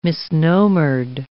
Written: misnomered

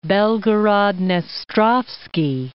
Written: Belgorod-Dnestrovskiy

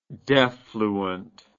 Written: defluent

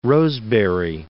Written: Rosebery

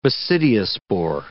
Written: basidiospore

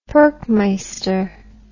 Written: perkmeister

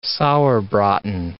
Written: sauerbraten

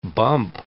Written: bump

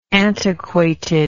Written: antiquatedness

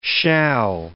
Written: shall

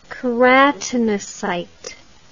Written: keratinocyte

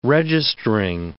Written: registering

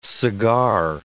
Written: cigar

